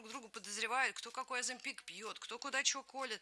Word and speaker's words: Оземпик: Поскольку идет программа наша с тобой, и следующая песня содержит рассказ мой, заготовленный рассказ, где фигурирует слово, вот это медпрепарат, который ты, Оземпик, причем ты правильно поставила ударение. В иностранном варианте Оземпик.